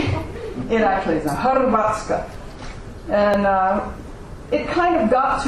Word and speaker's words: Hrvatska: They don't call it Croatia. They call it Hrvatska. – Hrvatska.